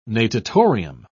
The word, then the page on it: natatorium: British English